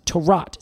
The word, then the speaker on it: Tarout: But it appears it's pronounced Tarot, spelled T-A-R-O-U-T.